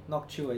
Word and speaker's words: Noctua: Noctua. – Noctua. – Noctua, yeah. – How do you spell that? – I just used to... – N-O-C-T-U-A.